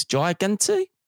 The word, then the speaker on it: Gigante: But listen, how good is that from Vincent Gigante? Is that how you pronounce it? G-I-G-A-N-T-E, boys. Gigante? – Gigante. – Gigante, yeah.